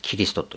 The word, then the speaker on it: キリスト: 日本だけはキという発音になる。キリストという。